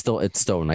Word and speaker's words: Stoughton: Stoughton. It's Stoughton. I got that down.